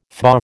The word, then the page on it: FBA: American English